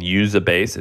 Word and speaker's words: Uzabase: Usabase? What's it called? – Yeah, it's a publicly traded company called Usabase. It's spelled Uzabase.